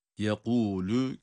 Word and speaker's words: yekûlü: Onun için olması gereken okuyuş yekûlü, yekûlü, ses budur. Ama yekûlü, yekûlü, bu, bu değil. Bu cezimli halinde çıkar. Yekûlü hem kalın hem de kalkale olmadan okunma şekli doğru olanıdır.